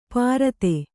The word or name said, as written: ಪ್ರಥೆ